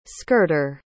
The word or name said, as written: skirter